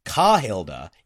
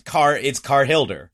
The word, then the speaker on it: Carhilda: And now I look at it, I'm like, oh, it's because it's not Ka, it's Car. – Yeah. – It's Carhilda.